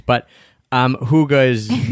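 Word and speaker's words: hygge: I want the huga, which is looks like it should be pronounced Haig, but it's huga, and it's this like essential it means like coziness, right?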